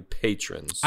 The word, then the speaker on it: patrons: I believe Is it new Patreons or is it new patrons? – I was going to say they're patrons on patrons. Patreon. – Yeah, no, I said that wrong, but thanks.